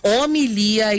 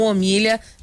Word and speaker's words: homilia: Castelo de Fortaleza, falando aqui sobre a pronúncia certa, homilia e não homilha.